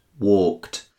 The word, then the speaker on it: walked: Walked. The N sounds like T. This is because the K at the end of walk is what's known as an unvoiced sound.